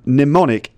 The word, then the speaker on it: mnemonic: Mnemonic is an interesting word. It has slightly weird spelling. It's spelled M-N-E-M-O-N-I-C, but the first M is silent. So it's actually pronounced mnemonic.